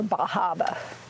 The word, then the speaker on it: Bar Harbor: Bar Harbor, or Bahaba, as they pronounce it.